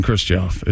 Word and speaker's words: Krzysztof: It's Krzysztof Gajewski. – No, there's another syllable in Krzysztof. It's Krzysztof.